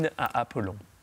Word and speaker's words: Hymne à Apollon: Gabriel Fauré, Hymne à Apollon, habe ich es richtig ausgesprochen? – Hymne à Apollon.